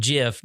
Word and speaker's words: GIF: The guy who created it says that it is pronounced GIF, just like the peanut butter.